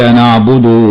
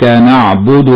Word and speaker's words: نعبد: ومن الخطأ تلاوتها نعبد بزيادة واو بعد الدال، فبعض الناس ينطقها خطأ بالمبالغة في ضمة الدال حتى يأتي بعدها بواو، وهذا خطأ، فينطق هكذا: إياك نعبد وإياك نستعين، وهذا خطأ. والصواب ضم الدال من غير مبالغة، هكذا: إياك نعبد وإياك نستعين.